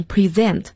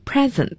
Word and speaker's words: present: Next one is present，大家要注意一下这个单词的发音，它做动词，所以要读成 present。如果做名词的话，读成 present，我们叫礼物。要注意，动词 present，名词 present。